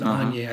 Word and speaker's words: Agni: It's spelled Agni, but you pronounce it Agnes, I guess.